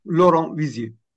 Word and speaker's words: Laurent Vizier: Sosyoloji profesörü Loren Vizier. Bilmiyorum doğru mu telaffuz ediyorum ama ya da Vizier. – Laurent Vizier. – Vizier tamam.